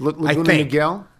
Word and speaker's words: Laguna Niguel: Is that how that's pronounced? N-I-G-E-L? – Laguna Niguel, I think. – Laguna Niguel.